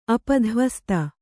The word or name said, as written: ಅಪಧ್ವಸ್ತ